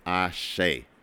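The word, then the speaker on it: Àṣẹ: And I probably mispronounced that song when I first announced it, but it's called Ashe. And it's spelled A-S-E. And the S has an under accent and the E has an under accent. So it's Ashe.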